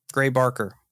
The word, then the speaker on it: Gray Barker: UFO writer Gary Baker described the... I don't know if I said his name right. Is it Gary Baker? Gray Baker. Gray Barker.